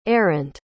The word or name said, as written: arent